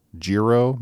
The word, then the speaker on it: Giro: Fender has teamed with, I think it's pronounced Giro, maybe G-I-R-O.